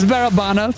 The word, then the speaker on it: Barabanov: I think I'm saying the name wrong, yeah. But he's a soft spot. Yeah. – It's Barabanov. – Barabanov. Yeah. I knew I had the timing of the word wrong.